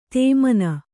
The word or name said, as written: ಧಮನಿ